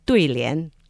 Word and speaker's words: duilian: Oh, Carol just mentioned the Chinese pronunciation of couplets. She said duilian. – Did I say it right? – Yeah, correctly.